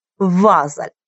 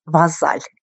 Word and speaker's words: Вазаль: только ударение на первый слог сделаю, Вазаль. Но нам очень приятно, и когда приезжали к нам французы, а такие у нас бывали в наших краях, они делали ударение, как всегда, во французском на последний слог, называя наше озеро Вазаль.